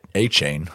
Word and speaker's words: Achane: Okay, so Dave, you took Devin Achane. I'm going to say that wrong about 7 million times. – You just said it right.